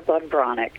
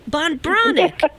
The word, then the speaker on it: Breathnach: It's pronounced Sarah Bonbronic, but I answer to Sarah. Yes, I answer to Sarah Van Breathnach. That's because you just, yes. – Bonbronic.